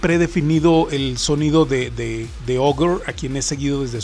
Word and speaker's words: ohGr: Ogre, que para quien no lo sepa, en el 2001 empezó a lanzar, Nive Cogre empezó a lanzar proyectos como solista bajo el nombre de Ogre, que se escribe O-H-G-R.